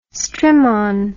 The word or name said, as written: strimon